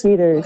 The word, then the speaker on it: Deters: Did I pronounce Deters. – That correctly? Deters? – Deters.